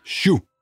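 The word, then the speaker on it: Chout: Og vores gode ven her i dag, Prokofjev, han får også sit gennembrud her, sit store gennembrud i hvert fald med balletten Shu. Jeg håber, jeg udtalte det rigtigt. – Ja, det tror jeg det.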